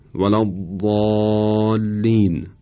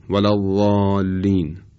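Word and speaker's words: ولا الضالین: به عنوان مثال مثلا ولا ضالین. اگر بدون اطباق بخوایم بگیم شاید اینطور بشه ولا ضالین.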